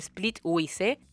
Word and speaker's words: Splitwise: W-I-S-E, Wise, Splitwise.